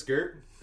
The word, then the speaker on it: Gert: Gert, my name is Gert. It is a one-syllable interpretation of my actual name, which is Garrett. But honestly, I prefer Gert, so let's just go with Gert.